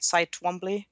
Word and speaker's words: Cy Twombly: Is that how you say it? Cy Twombly? – Yeah, you got it.